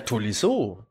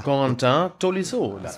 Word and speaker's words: Corentin Tolisso: Ja, Tolisso. Tolisso, ne? – Corentin. – Ah. Corentin Tolisso.